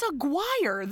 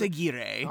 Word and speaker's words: Aguirre: In his second Kicking and Streaming appearance, Mr. Randy Quaid as Joe Aguirre. – I hate how that's pronounced. – The sheep herder man, the sheep herder foreman. – It's Aguirre. That's how that's spelled. – It's Aguirre.